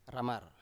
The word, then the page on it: ramar: Biak